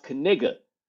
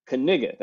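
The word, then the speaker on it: Knigga: And his last name is spelled K-N-I-G-G-A. K-N-I-G-G-A. And we didn't know how to pronounce it. And then we did the internet searching, because his dad is a coach. His dad is his coach. And so we Googled it, and we found out that it's pronounced Kaniga. Kaniga.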